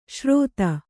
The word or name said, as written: ಶ್ರೋತ